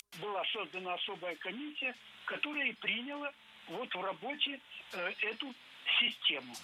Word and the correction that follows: приняла: Не приняла, а приняла. Ударение в этом глаголе ставим на окончание.